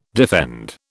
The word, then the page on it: defend: British English